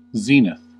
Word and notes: zenith: American English; [ˈzi.nɪθ]

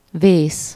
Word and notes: perish: /ˈpɛɹɪʃ/

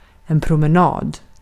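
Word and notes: promenad: Swedish

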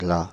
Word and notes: bell: /bɛl/

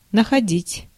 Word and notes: находить: /nəxɐˈdʲɪtʲ/

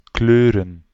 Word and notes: paint: /peɪnt/

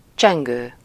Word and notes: bell: /bɛl/; American English; [bɛl]

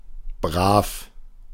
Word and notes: good: /ˈɡʊd/

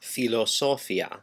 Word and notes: philosophie: France; [fi.lɔ.zɔ.fi]

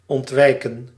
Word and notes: prévenir: France; /pʁev.niʁ/; /pʁe.və.niʁ/

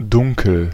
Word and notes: dunkel: /ˈdʊŋ.kəl/; [ˈdʊŋkl̩]

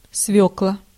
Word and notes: свёкла: [ˈsvʲɵklə]

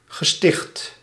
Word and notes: institut: [ɛ̃s.ti.ty]; France

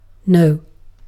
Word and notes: ei: /ˈei/

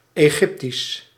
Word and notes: Egyptian: /ɪˈdʒɪp.ʃən/

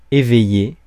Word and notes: éveillé: [e.vɛ.je]; France

